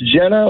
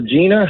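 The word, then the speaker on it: Genna: It's Jenna or Gina, however you want to say it. It's spelled with a G, G-E-N-N-A.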